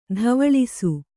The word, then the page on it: ದುವ್ವಾಳಿಸು: duvvāḷisu